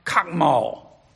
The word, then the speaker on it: chokmah: Yet the Hebrew term for wisdom is C-H-O-K-M-A, pronounced chokmah. That's Hebrew. It comes from the base word in Hebrew, chokmah, two different words, meaning to be wise.